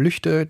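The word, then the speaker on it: Lügde: Denn viele Menschen, die nicht im Nordrhein-Westfälischen oder im Westfälischen oder eigentlich müsste man sagen im Lippischen leben, denen wird die Aussprache Lüchte angesichts der Schreibweise erstmal eigentümlich vorkommen und halten das vielleicht für falsch.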